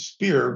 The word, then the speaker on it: Spier: We pronounced it spear because it's S-P-I-E-R, and that's the German word for muscle.